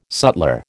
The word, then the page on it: sutler: American English